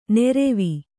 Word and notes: ನೆರೆವಿ: neravi

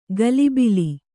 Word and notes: ಗಲಿಬಿಲಿ: Kannada

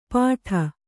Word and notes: ಪಾಠ: kannada; pāṭha

